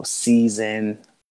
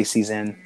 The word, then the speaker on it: Seizen: I've heard Sizen. People pronounce the E a little harder, so season. I just say season. – Seated. – like food seasoning, I say it like Yeah. – season. You know what I mean? I say it like that, but a little bit more pronunciation on the Z.